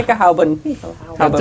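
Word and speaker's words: Pickelhaube: How to pronounce pickle hob. – They were getting guns on us. – Right. – And not pickle guns. – What? And what? – Sirens. – Picklehoven. – Picklehalbe. – Picklehalbe. – Haube. H-A-U-B-E. – Yeah. – Haube. – Picklehauben. – There are no silent letters in German.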